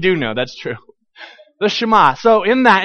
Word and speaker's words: Shema: And we were having a conversation about, I don't even recall exactly how we got onto the conversation, but it trended toward the Shema, the Shema, depending on who you're talking to. Did I say that? Did I pronounce that correctly? What would you say? Shema? Okay, all right, fair enough.